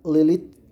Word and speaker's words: Lilith: pero se pronuncia Lilith, Lilith pero con, con H al último, o sea, L, eh, T, H, perdón.